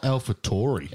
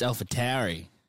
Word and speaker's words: AlphaTauri: Well, because it's not AlphaTauri. – It's AlphaTauri. – Tauri.